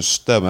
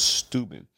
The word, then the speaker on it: Steuben: D-E-S-I. DC. It's an Indian. The Steuben County. Is that how I pronounce it? Steuben or Stubben? I think it was Steuben.